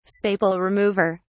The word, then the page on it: staple remover: British English